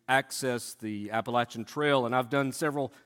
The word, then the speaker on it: Appalachian: I've always been fascinated a bit with the Appalachian Trail, and that is how you say it. Not Appalachian, that's a bad word in my family. Appalachian Trail.